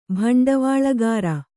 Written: ಭಂಡವಾಳುಗಾರ

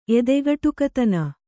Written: ಎದೆಗಟುಕತನ

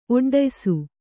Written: ಒಡ್ಡಯಿಸು

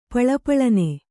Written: ಪಳಪಳನೆ

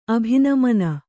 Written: ಅಭಿನಮನ

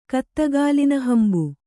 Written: ಕತ್ತಗಾಲಿನಹಂಬು

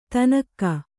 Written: ಥಣ್ಣಗೆ

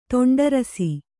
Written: ತುಂಡರಿಸು

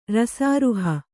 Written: ರಸಾರುಹ

